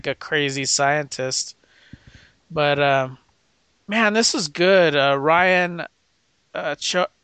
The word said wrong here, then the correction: Choi: I'm gonna pronounce his name wrong. Choi, I think that's Choi. Choi. – Yeah.